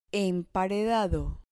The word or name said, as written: emparedado